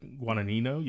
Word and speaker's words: Guadagnino: Guadagnino, yeah.